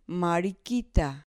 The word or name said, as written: mariquita